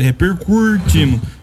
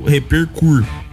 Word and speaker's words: repercutimos: Você está falando certo. Repercutimos. Repercutimos é certo. Ele falou repercur. Fala repercur.